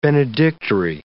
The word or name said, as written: benedictory